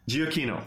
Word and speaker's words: Giacchino: I know that the Michael Giacchino. How do you pronounce his name? – Giacchino.